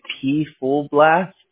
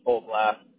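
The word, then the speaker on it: PFullBlast: Full Blast. – It's just Full Blast? – Yeah, the same way you pronounce phone. I'm not, like, trying to be mean. It's just everyone pronounces it people.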